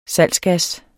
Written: salgsgas

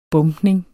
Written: bombning

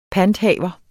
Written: PANTHAVER

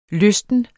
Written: lysten

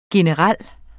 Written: generel

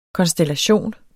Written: KONSTELLATIONER

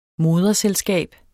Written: moderselskab

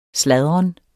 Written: sladren

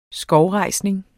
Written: skovrejsning

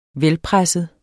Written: velpresset

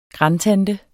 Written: grandtante

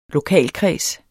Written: lokalkreds

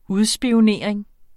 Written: UDSPIONERING

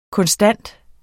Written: konstant

